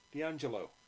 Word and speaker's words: D'Angelo: D'Angelo.